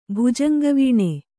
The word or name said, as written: ಭುಜಂಗವೀಣೆ